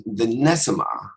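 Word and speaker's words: nesama (נשמה): See, the spirit, the nesama, that's how you say it if you go into Hebrew, nesama. N-E-S-A-M-A-H, Nesama, however you want to pronounce it.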